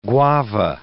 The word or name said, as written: guava